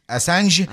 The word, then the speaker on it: Assange: Assange.